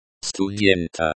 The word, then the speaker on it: студента: The stress in the word. Студента. Студента. Is on the second syllable.